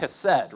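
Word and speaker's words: chesed (חסד): And that's because the word God uses to describe himself here, again, it's pronounced chesed, right?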